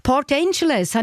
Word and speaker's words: Port Angeles: Die Elisabeth Hegi von Port Angeles, habe ich es richtig gesagt? – Ja, das ist richtig.